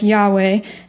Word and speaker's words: Yahweh: In Hebrew, this word is pronounced like Yahweh, and it's built on the word for I am.